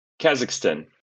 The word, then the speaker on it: Kazakhstan: Please tell me how to properly pronounce Kazakhstan. Is that correct? – Kazakhstan. – Kazakhstan. Why am I introducing another I in there? – No worries.